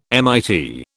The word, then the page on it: MIT: British English